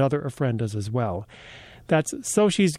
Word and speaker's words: Xochi: Another place in Detroit you can see some ofrendas this weekend is Soshi's Gift Shop. Soshi's is spelled X-O-C-H-I.